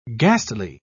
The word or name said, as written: ghastly